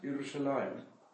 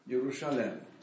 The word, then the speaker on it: Yerushalayim: But in all the other instances, the vast majority, it's written without a Yud, and nevertheless it's pronounced Yerushalayim. It's what we call a permanent kriya kativ.